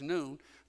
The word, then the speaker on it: Nun: The Joshua who succeeded Moses, Joshua the son of Nun, and I know that looks like Nun, but the Hebrew pronunciation is Nun, Joshua the son of Nun.